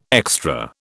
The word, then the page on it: extra: British English